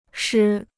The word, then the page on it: 诗: shī